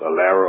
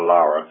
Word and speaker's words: ALARA: That thing, as low as reasonably achievable, which is pronounced ALARA or ALARA, anybody and everybody that does any work in radiation should understand that acronym.